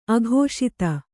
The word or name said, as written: ಅಕ್ಷತೆ